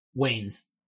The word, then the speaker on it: Wayans: Wayans.